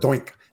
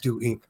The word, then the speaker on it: DoInk: I think it is pronounced DoInk. I remember when I used to call it DoInk. I would even do presentations on DoInk and someone said, it's DoInk.